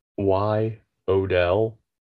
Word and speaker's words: Y Odell: was that the character that Slim went by was Y Odell, Yodel. – Which spells Yodel.